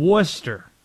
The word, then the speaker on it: Worcester: How about we go to Susan, who's in Worcester? I nailed that, right? That's the proper pronunciation.